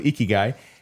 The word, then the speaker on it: Ikigai: Ikigai, I hope I'm pronouncing that correctly. I've been corrected multiple times, so I'm thinking I'm saying that right. – Yes. – Okay, yes. – Good. So Ikigai.